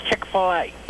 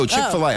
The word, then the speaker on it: Chick-fil-A: It's Chick-fil-A. – Oh, Chick-fil-A. I'm sorry. I said it wrong.